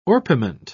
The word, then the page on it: orpiment: British English